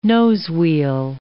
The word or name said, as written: nosewheel